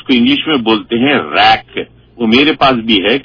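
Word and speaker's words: RAIC: تو R A I C spelling ہے اس کی شارٹ فارم میں, تو اس کو ریک بولتے ہیں.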